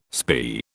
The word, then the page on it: spae: British English